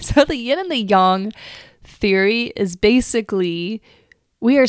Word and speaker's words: yang: Well, the truth is, my friend, Americans say yang funny. I remember in one of my first classes, Dr. Chang, she was like, what does yang? Why are you all saying yang? It's yang. Yang, Y-A-N-G, is pronounced yang.